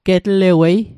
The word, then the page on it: getlewei: Mi'gmaq